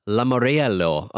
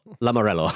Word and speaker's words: Lamoriello: Lamoriello. Oh, no, the I is not pronounced, so Lamoriello.